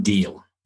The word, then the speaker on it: Deel: there's John Diehl, and it's spelled D-E-E-L, and there's no website.